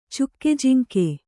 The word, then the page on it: ಚುಕ್ಕೆಜಿಂಕೆ: cukke jiŋke